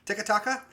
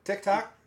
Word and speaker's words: TikTok: Tikataka, however you say it. Is that, am I saying it right? Tikatok? – TikTok.